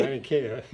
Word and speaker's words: Laniakea: Laniakea supercluster. – It's a supercluster. – And can you say it? – Yeah, supercluster. – No, not that one. Very funny. – Laniakea.